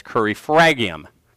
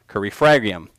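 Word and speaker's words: crurifragium: Now, this is what is known in Latin as curry fragium. Curry fragium, okay? A lot of R's in there that I'm trying to not pronounce, but it means the breaking of the shin bone, and you can actually hear from fragium, our English term for fragments.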